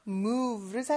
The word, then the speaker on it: move: 우리 V 발음 어떻게 하는지 혹시 아세요? 윗니를 아랫입술에 살짝 댄 상태에서 V라고 하는 것처럼 진동을 시켜주는 발음이에요. 이렇게요. V, V, 요겁니다. 약간 휴대전화가 진동하는 소리 같죠? move, 살짝만 해주는 거죠. move, move. 요 진동하는 요 소리에 익숙해지셔야 돼요.